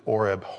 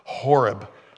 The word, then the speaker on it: Horeb: Horeb, I always say it like Mexican. Mexicans don't pronounce H's. Horeb, the mountain of God.